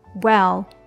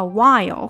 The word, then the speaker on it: well: well 啊，不要读成了 while 或者是 will 都不对哈，它是 well well。Oh God well。It started about a half hour before the wedding。我们把它放慢。It started about a half hour before the wedding.